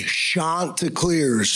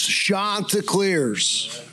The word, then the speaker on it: Chanticleers: All right, so for people that are not initiated from this part of the country, there is a certain way you pronounce Coastal Carolina's mascot. I give you Coastal Carolina head coach, Kevin Schnall. – Everybody say it with me. Chanticleers. Chanticleers. Not Chanticleers. Chanticleers.